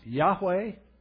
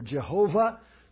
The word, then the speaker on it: Yahweh: It's YHWH, which we pronounce Yahweh or Jehovah. Both of them are right, just different ways of putting the vowel pointings in.